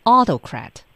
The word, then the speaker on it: autocrat: 哎，它是一个 auto 加上 crat，读音呢是 autocrat， autocrat。 – 对，重音呢在前啊，大家不要以为哟，这是 auto，我就 autocrat 了啊，它往前去读，对吧？好，叫做 autocrat。